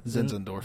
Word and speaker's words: Zinzendorf: Zinzendorf.